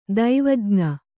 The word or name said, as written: ದೈವಜ್ಞ